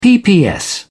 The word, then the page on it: pps: British English